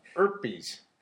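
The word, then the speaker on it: Ypres: it's Y-P-R-E-S. I learned how to spell it, or I learned how to say it, and now I've forgotten. – Erpes. – It's like ease, or ALF, okay. or it's something very non-intuitive.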